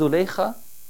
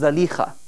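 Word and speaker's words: Zulaikha: Nabi Yusuf in the Quran, and the wife of the Aziz, usually we refer to her as Zuleikha, although the more correct pronunciation is Zalikha.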